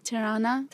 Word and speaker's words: Tirana: Tirana. – Tirana.